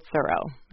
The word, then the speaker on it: Thoreau: Perhaps no American writer is more associated with a place than Henry David Thoreau. And that, by the way, is the family pronunciation.